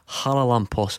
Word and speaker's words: Charalampos: So although it's spelled Charalampos, uh-huh. he says it's just Haralampos.